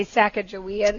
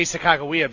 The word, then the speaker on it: Sacagawea: Sacagawea. – Because we're supposed to say Sacagawea now. – Yeah, but I say Sacagawea because the people who are on that lake, that's how they say it.